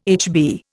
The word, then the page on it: HB: Indian